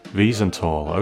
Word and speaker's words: Wiesenthal: The capture of Eichmann, thanks to the Mossad and the relentless efforts of Nazi hunter Simon Wiesenthal, pronounced Wiesenthal, okay, cool, thank you for that, Andrew.